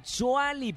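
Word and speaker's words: Tzuali: ¡Qué buena onda, Chuali! Pero se escribe con T al principio, ¿no?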